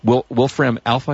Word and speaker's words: Wolfram Alpha: So you can say, Siri, settle my argument, and it'll plug into Wolfram Alpha, I guess it's pronounced.